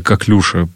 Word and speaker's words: коклюша: Да, именно коклюша, ударение на последний слог, французское слово.